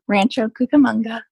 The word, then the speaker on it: Rancho Cucamonga: Chukamunga. – Yep. – I don't even know if I pronounced that right. Yes. – Yeah. Rancho Cucamonga.